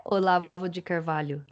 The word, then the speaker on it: Olavo de Carvalho: yeah, um, I, I, you can pronounce it properly for me, but uh, Olavo de Car- Carvalho. Yeah, Olavo de Carvalho.